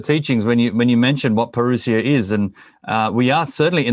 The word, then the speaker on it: parousia: As you can see, I mean, I've got logos behind me that says the word parousia, or parousia is actually the way to pronounce it. In Australia, we just said parousia. It's an Aussie thing.